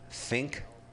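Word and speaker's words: Pfink: It's a movie called Rat Fink a Boo Boo. – But the Fink is with a P. It has a PF. – Yeah, right. The P is silent in Fink.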